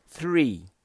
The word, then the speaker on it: three: O correto é three, O som do TH é um som estranho ao sistema fonético da língua portuguesa, então a gente precisa treinar a língua para falar corretamente, pronunciar corretamente esse som. Então a gente fala three. Repeat after me. Three. O segredo está em você encostar a língua entre os dentes.